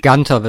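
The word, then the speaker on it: Guenther: Gunter wird er ausgesprochen. – Ja, da wird aber mit UE geschrieben. – Naja, ich glaube, der wird Gunter in Amerika ausgesprochen.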